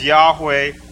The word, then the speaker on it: Yahweh: And the Hebrew word that is used is, we pronounce it as Yahweh. Scholars debate about the actual pronunciation of that. That's the closest pronunciation we know of.